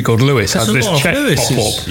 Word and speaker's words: Lewes: There's a town in East Sussex called Lewis, but it's spelled L-E-W-E-S.